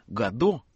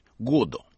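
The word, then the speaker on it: Godot: Даже имя Гадо, как мы привыкли произносить, у него звучит так, как произносил сам Беккет с ударением на первом слоге – Годо.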